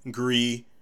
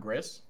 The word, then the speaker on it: Gris: So, wait, it's pronounced Gree, not Gris? – I think it's Gree, because I think it's like gray in French.